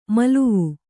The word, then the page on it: ಮಲುವು: maluvu